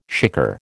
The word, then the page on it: shikar: American English